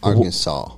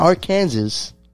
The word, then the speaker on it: Arkansas: Sir, Arkansas. – Wow. Damn, I was going to let them guess which state. Now, let me stop. But yeah, he's in Arkansas. Not yeah. Arkansas. Arkansas.